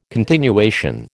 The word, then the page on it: continuation: American English